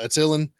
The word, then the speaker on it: Attilan: Attilan, yeah, Attilan.